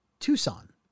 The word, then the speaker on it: Tucson: And just so you know, the car is pronounced Tucson after Tucson, Arizona.